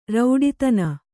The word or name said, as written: ರೌಡಿತನ